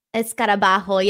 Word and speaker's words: escarabajo: Escarabajo, yeah. Like, the hole, the J is someone pronounced like an H.